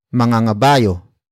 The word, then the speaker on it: mangangabayo: Our final example, mangangabayo siya sa makalawa. Mangangabayo siya sa makalawa. Mangangabayo siya sa makalawa. It means that person will ride a horse 2 days from now. Word for word it's mangangabayo, siya, sa, makalawa.